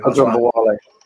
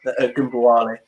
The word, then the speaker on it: Ogunbowale: I'm going to butcher that name. – Ogunbowale. – Ogunbowale. Yeah, there you go.